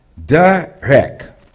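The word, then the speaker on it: derech (דרך): Now, the word in Hebrew is pronounced derech.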